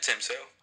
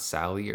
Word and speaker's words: Sale: Yeah, Tim Sale. – It's not Sally or anything like that. Sale? Okay.